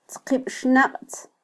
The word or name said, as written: tzqibxnaqtz